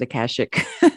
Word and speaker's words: Akashic: And all the practices that you do, EFT tapping, the Reiki energy healing, the Akashic records or Akashic records. I pronounce it Akashic.